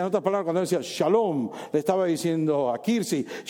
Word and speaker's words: shalom: Y la palabra paz en el hebreo significa, o se pronuncia como shalom, ¿verdad?